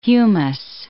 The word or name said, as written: humus